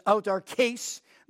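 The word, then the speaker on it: autarkēs (αὐτάρκης): It's not that you need to know Greek words, but it's autarkēs. That long E, when it's transliterated into English, has kind of an A sound.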